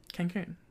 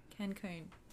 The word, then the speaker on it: Cancun: how do you say it? – Cancun. – Cancun. There you go.